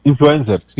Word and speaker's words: influencers: Usted conoce muchos aspectos, es que el presidente Duque la semana pasada pues, reunió a todos los jóvenes que tienen ¿Cómo es que se le dice influencer? Eh, en inglés, ¿cómo se dice? Influencers. Influencers. Influencers.